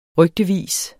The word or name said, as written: rygtevis